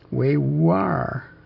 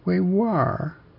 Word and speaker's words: Uyghur: the word that's spelled U-Y-G-H-U-R in English. Wei-war. Wei-war.